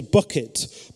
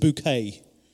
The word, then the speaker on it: Bucket: And so, for example, her surname was Bucket, but she would insist that everybody pronounce it Bouquet.